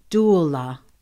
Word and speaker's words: doula: She is a consciousness doula. Did I say that right? – Doula. That's correct.